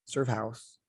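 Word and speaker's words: Surf Haus: Surf House. I say it like that because it's spelled H-A-U-S, like German.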